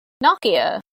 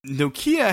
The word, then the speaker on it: Nokia: How do you say it? – Nokia. that's how the brand is pronounced in the UK.